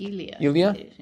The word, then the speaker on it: Elia: Elia.